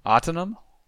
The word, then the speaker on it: Atanum: How did you say it? – Autonom. – Autonom. That sounds right.